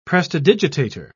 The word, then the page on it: prestidigitator: British English